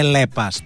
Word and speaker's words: Lepas: Προφέρεται Λέπας, το ξέρετε, δεν είναι το είπα λάθος.